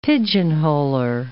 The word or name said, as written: pigeonholer